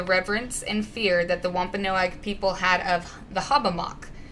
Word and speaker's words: Wampanoag: Yeah, no, I heard that. You said it right. – I didn't want to mess it up. I was like, that's disrespectful. The Wampanoag tribe used the wetland for its fertile hunting ground, and they also used it as a sacred burial ground.